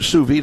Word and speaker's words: sous vide: But sous vide is the way that I've heard. I watch a lot of the cooking channel and the Food Network, and that's the way I've heard it pronounced there.